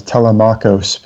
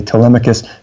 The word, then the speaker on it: Telemachus: Young Telemachus, when I was little, I thought it was Telemachos because that's how it's spelled. But usually people say Telemachus.